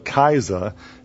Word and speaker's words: Kaiser: In between the First Reich and the Third Reich, you had the Second Reich, which was the German Kaisers. It's interesting, the word Kaiser is pronounced exactly the same as the word Caesar was pronounced in Aramaic, Kaiser.